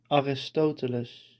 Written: Aristoteles

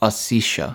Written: Ossetia

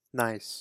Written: Nice

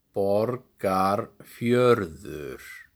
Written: Borgarfjörður

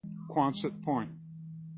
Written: Quonset Point

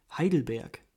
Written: Heidelberg

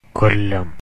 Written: Kollam

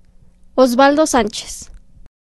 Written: Oswaldo Sanchez

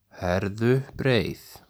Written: Herðubreið

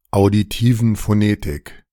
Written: Auditiven Phonetik

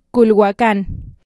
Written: Cualhuacán

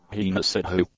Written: heena sidhu

